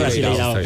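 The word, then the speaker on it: Brasileirão: está en el Brasileirao. ¿Sí? ¿Se dice Brasileirao? ¿Cómo se dice? – Sí, Brasileirao.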